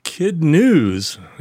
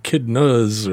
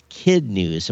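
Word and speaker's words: KidNuz: But, yeah, it's Kid News, and you were saying Kid Nuz or something like that. – Yeah, I was like, and I thought, this guy's having a hard time, you know, I didn't know how to pronounce. Yeah, it was Kid News or Kid Nuz or something like that, because I was pronouncing it as it's spelled, KADNUZ, but it's Kid News.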